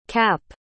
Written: kapp

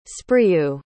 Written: spreeuw

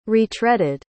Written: retreaded

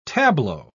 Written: tableau

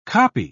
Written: kopje

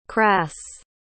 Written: kras

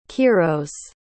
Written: kiros

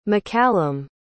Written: maccallum

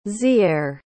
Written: ziarre

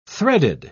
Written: threaded

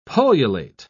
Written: pullulate

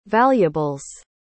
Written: valuables

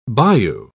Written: bayou

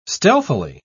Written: stealthily

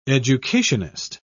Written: educationist

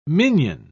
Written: minion

